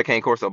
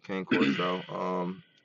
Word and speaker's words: cane corso: Anyway, I got a cane corso, by the way. – She's everything, y'all. – Black female cane corso. It's an Italian Mastiff. Technically, it's pronounced Cane Corso. I'm well aware, but y'all know how Black people do.